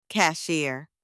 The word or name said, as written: cashier